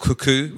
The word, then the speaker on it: kuku: There's another thing called kuku, which is a famous Azeri Iranian dish, which is like their version, like an omelette, basically. – Oh, okay. Kuku. How do you spell it? – K-U-K-U. – K-U-K-U. – Yeah. – K-U-K-U.